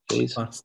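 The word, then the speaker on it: Faes: Faze, or Faz, is that how you pronounce him? – Faze.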